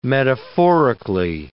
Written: metaphorically

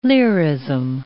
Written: lyrism

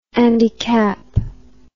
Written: Andy Capp

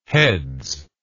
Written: heads

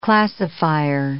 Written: classifier

